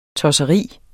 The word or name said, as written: tosseri